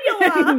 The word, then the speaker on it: cannula: How the fuck you pronounce that? – Cannula.